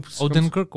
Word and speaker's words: Odenkirk: Fait que ajoute Bob Odenkirk, c'est ça comme. – Odenkirk, ouais.